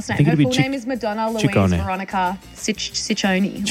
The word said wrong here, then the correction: Ciccone: Well, in Italian, it works the other way. So the double C's are hard C's and the singular C's are soft C's. – So Ciccione. – Ciccone.